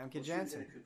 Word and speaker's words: Famke Janssen: Famke Janssen.